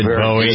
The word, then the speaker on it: Bowie: Notice I pronounced it right, Kenny? Richie? – Yay. – David Bowie.